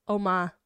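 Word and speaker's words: Umma: We have Uma and we have The Outfit. Uma was the one that you were talking about with... – It's not pronounced Uma. It's Oma.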